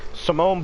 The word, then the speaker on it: Simone: Simon Bowles. Oh, I'm sorry. I, I did not mean to mispronounce a name like that. I said Simon too. Simone Biles.